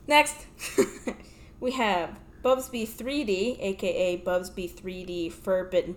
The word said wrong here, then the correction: Bubsy: Bubsy, bro, not Bubsby.